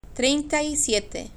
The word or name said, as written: trienta y siete